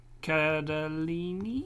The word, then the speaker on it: Cardellini: Cardellini.